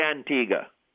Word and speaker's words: Antigua: Well, here in Antigua, we say Antigua. It's probably the only silent U in the English language because it's originally a Spanish word.